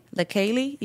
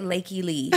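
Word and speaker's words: Lakeyah: That's how you pronounce her name? – LaKaylee? Yeah. – I was pronouncing it Lakey Lee.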